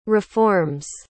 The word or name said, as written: reforms